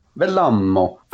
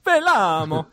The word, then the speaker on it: Vellamo: It's spelled V-E-L-L-A-M-O. Does either one of you speak Finnish? – Velamo. – Velamo.